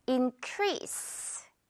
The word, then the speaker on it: increase: 자, 그런데 많은 한국인들이 increase 이렇게 마지막 이 SE를 약간 Z로 알고 있는 것 같아요. 자, 그런데 이제 여러분은 그러지 않을 수 있겠죠. increase 역시 S 발음이에요.